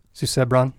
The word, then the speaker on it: Susebron: How do you guys pronounce the name of the God King? How does the audiobook do it? – Susebron. – Susebron? Okay, so I was a little off on that.